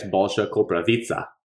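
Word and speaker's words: Balša Koprivica: Luka Garza and Balsha Koprivica, if that's how you pronounce it. That's how you put it in there. – Yeah, it's Balsha Koprivica, like pizza.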